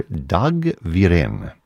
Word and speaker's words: Wirén: First, this by the Swedish composer Dag Wirén. Last name is spelled W-I-R-E-N, and the piece for which he probably is best known, The String Serenade.